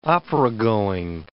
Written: operagoing

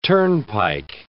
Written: turnpike

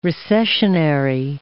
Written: recessionary

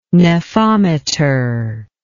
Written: nephometer

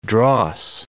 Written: dross

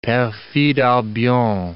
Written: perfide Albion

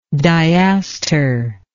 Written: diaster

